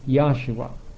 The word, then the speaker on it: Yahshua: Now Joshua, pronounced as it would be in Hebrew, since there's no J in Hebrew, would be just like the pronunciation of the J in Hallelujah, and that would be Yahshua. And therefore, to make the spelling of it more clear to those that would read it for the first time, instead of spelling it J or Y-O-S-H-U-A, we spell it Y-A-H-S-H-U-A.